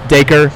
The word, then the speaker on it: Dacre: Dacre Montgomery, who played Billy Hargrove. – Dacre. – Dacre, which is a strange name.